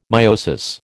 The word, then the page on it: meiosis: American English